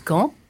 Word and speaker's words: Kant: Alors, ça s'écrit comme Kant, le philosophe, mais ça se prononce Kant, comme dans la phrase « dit quand reviendras-tu » ou « quand je pense à Fernande », un mot qui peut être conjonction ou adverbe selon.